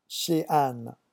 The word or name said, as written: chez Anne